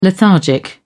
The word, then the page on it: lethargic: British English